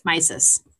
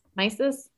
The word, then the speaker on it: Missis: One day while walking Pongo and Mrs. Mrs. is spelled M-I-S-S-I-S. And so I'm like, were we trying to go with Mississippi and we forgot how to spell it? It's Mrs. Mises. – Mises.